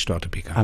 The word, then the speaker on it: Störtebeker: Sondern dann wäre das ja, wird der Vokal kurz gesprochen, so wird der langgezogen, heißt eigentlich Störtebeker.